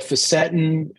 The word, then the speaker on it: fisetin: I think you did some work on, I always pronounce this incorrectly, fisetin. – People call it facetin. As long as you're not facetious about it, we don't really care. You can call it what you like.